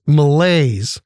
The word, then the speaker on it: malaise: It's from the French malaise. Two syllables, M-A-L-A-I-S-E.